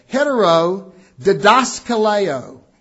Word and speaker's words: heterodidaskaleo: Heterodiskaleo. I'm saying it wrong. I'm trying to emphasize it wrong. Hetero Didaskaleo. Heterodidaskaleo. Big long word.